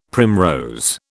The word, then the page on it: primrose: British English